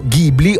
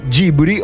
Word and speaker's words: Ghibli: Ghibli o studio Ghibli. Dipende, perché le tre pronunce sono Ghibli alla giapponese, Ghibli all'italiano, nel momento in cui lo studio prende il nome da un famoso aeroplano, perché...